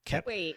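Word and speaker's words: Koepp: And in one of those weird turn of events, he was also a co-writer on this film, along with David, is it Cope? Kep? How do you pronounce his name? – Kep.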